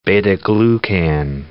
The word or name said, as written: beta-glucan